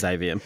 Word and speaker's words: Aveum: Am I pronouncing that correctly? Is it Avium? – I don't really know. I've forgotten, but yeah. Yeah, it is Avium.